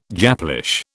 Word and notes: Japlish: British English

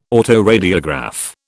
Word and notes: autoradiograph: British English

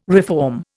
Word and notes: reform: Indian

